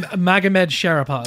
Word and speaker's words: Magomed Sharipov: Zabit Maramug Sharipov, how do you say his name? – Magomed Sharipov. – Magomed Sharipov, I'll get it.